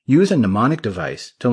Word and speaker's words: mnemonic: A mnemonic device will make this even easier. A what device? Mnemonic, the first M in the word is silent, came from a Greek word meaning to remember.